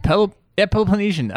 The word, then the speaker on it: Peloponnesian: Peloponnesian.